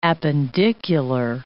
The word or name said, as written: appendicular